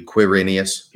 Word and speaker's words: Quirinius: But I think that the way it's scholars understand it to be pronounced is like Q-U, Quirinius.